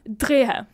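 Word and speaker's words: Dreher: Vilket jag bara vet hur man uttalar för att jag kollade på en ölhistorievideo på tyska för att kunna uttala det här efternamnet korrekt. En gång till, Drehe. Drehe.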